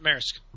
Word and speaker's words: Maersk: Maersk. Pronounced Maersk.